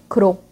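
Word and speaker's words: Gros: Então me perdoem aí o erro na pronúncia, mas enfim, portuguesando aqui o nome do cara, o autor que a gente vai estudar é o Frederic Croh, né? Que se escreve Gross. Então, o Croh, pelo menos, eu consigo falar mais ou menos, né? Mas imagina que você escutou, então, Frederic com aquele Rzinho francês lá no meio, que no meu caso é impossível de fazer, tá bom?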